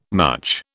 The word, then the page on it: much: British English